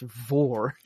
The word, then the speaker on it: Vor: Well, she has the same spelling as Vor from Warframe, and that's pronounced Vor.